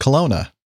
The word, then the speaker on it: Kelowna: She says, "I would have said it's pronounced Kelowna." And Oh, I'm right.